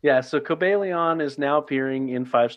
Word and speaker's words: Cobalion: Uh, so it is pronounced, according to my book, Cobalion.